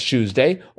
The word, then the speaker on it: Tuesday: In this variation, the T in Tuesday is pronounced as a soft ch sound, similar to the way tune is sometimes pronounced as tune in these accents.